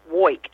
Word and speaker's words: Woik: Well, my last name is pronounced Woik, and how I say that is I woik all day and I woik all night, and that is about correct, so... – And it's W-O-I-K.